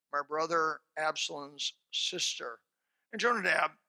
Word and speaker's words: Jonadab: I call him Jonadab. Some pronounce it Jonadab, but it's Jonadab.